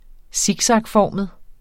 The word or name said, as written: siksakformet